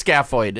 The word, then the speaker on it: scaphoid: Well, I called him scaphoid. He called him scapular. So I don't know. I'm sorry. It is scaphoid.